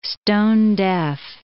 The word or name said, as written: stone-deaf